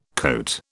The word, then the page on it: cote: British English